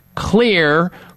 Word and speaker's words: Xlear: And the way that I suggest you do is to use Clear Rescue Nasal Spray. That's spelled X-L-E-A-R. Clear is spelled X-L-E-A-R.